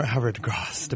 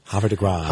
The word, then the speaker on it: Havre de Grace: They're a gospel group, of course, from, I'm going to pronounce this correctly here, from Havre de Grace, Maryland. – Yeah, or Havre de Grasse, depending. – Havre de Grasse.